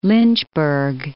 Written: Lynchburg

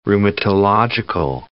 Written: rheumatological

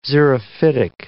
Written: xerophytic

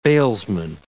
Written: bailsman

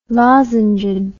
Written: lozenged

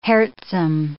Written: heartsome

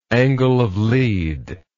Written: angle of lead

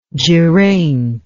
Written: geraint

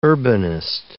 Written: urbanist

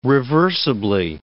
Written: reversibly

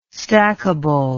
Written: stackable

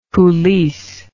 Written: coulisse

